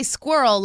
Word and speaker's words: squirrel: I say squirrel like it rhymes with girl.